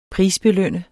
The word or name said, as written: prisbelønne